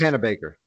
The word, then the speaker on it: Panabaker: Panabaker.